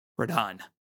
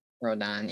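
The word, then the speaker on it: Rodón: Rodon. – Rodon, yeah.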